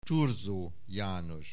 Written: Thurzó János

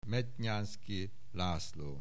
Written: Mednyánszky László

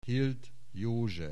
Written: Hild József

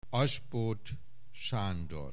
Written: Asbóth, Sándor

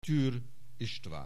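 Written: Türr István

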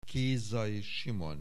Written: Kézai Simon

